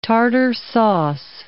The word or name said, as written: tartare sauce